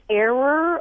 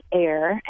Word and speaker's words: err: You would think it would be like error or errant, but no, the traditional pronunciation for a long time was err. And, William Sapphire once said that when enough of us say it wrong, we'll be right. And more of us are saying it as air, and I imagine that in another generation it will be air.